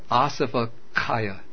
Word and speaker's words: asavakkhaya: This is called asava kaya, long A, S-A-V-A, K-K-H-A-Y-A.